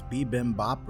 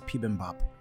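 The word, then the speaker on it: Pibimbap (비빔밥): Sometimes anglicized as bibimbap or bibimbap. – Wow. – It's a Korean dish. – Huh. – I think it's pibimbap. Pibimbap. – Pibimbap. – Is the Korean pronunciation. – Wow. – The word literally means mixed rice. – What's the spelling? – B-I-B-I-M-B-A-P.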